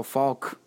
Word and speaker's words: Faulk: But that was my problem when I used to tell you how much I loved Marshall Faulk, but I couldn't say I just wouldn't pronounce the L growing up. It's like, oh, the one Steeler I love, Marshall Faulk. You guys you're not saying it right.